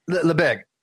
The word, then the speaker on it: Lebesgue: but is it Lebesgue? Is that how you pronounce it? – Lebesgue. – Okay. Lebesgue. Is it French? – Yes.